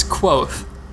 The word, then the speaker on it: Kvothe: Kvothe. I'm reading the book, but of course I'm reading it in my head, not out loud. – It's Kvothe. – Kvothe, got it. Okay. So, I have Kvothe, mythic adventurer recounting his journeys as a younger, inexperienced warrior.